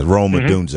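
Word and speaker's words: Rome Odunze: Yeah, Roma Odunze.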